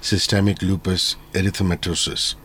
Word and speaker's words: systemic lupus erythematosus: Let's start with a basic definition of systemic lupus erythematosus. Is that a correct pronunciation? – That's absolutely correct.